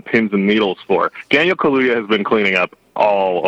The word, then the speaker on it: Kaluuya: Daniel, I always say Kaluuya, but it's Kaluuya.